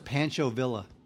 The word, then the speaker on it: Pancho Villa: I don't know what else it's pronounced Pancho Villa.